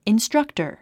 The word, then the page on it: instructor: instrʌ́ktə r; インストラクタァ